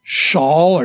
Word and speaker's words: Shaul: Hebrew pronunciation was probably Shaul or Shaul.